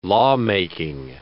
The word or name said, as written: lawmaking